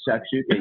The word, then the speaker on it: shakshuka: Now, when I was in your book, I watched a video that you made on a dish called shakshuka. Can you pronounce that for me? – Yeah, it's shakshuka.